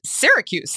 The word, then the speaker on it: Syracuse: Syracuse is pronounced Syracuse. Like, I'm from Syracuse. I know how to say Syria. Why am I putting it in an Italian accent?